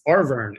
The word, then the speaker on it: Arverne: Wait, did we say that right? It's Arverne. – No, it was closer before. It's Arverne.